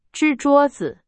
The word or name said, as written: 支